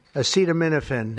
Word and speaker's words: acetaminophen: Acetaminophen.